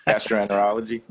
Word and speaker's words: gastroenterology: And this gives the categories, it gives the biomarker, it gives the therapeutic area, such as infectious disease, oncology, psychiatric medication, anesthesiology, gastroenterology. How do you say that? – Gastroenterology.